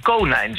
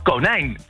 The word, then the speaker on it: konijn: En dat kun je ook horen aan de uitspraak. Want anders zouden we misschien konijn zeggen. Hè? Want inheemse woorden hebben vaak de klemtoon op de eerste lettergreep. Denk maar aan dieren als het veulen of het kuiken. Maar wij zeggen konijn. En dat komt dus uit het Frans, want daar ligt de klemtoon vaak op de tweede lettergreep.